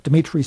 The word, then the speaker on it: Dimitri: Dimitri spelled with three I's. D-I-M-I-T-R-I.